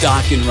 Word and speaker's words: Dawkins: You know, I like how it says in parentheses behind the word Dawkins that it rhymes with rockin'. Like, we're going to hire a part-timer that just might not know that. It's Dawkins Rifle, of course, burning like a flame.